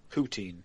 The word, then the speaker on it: poutine: How do you pronounce that? – Poutine. – Poutine. I always call it poutine.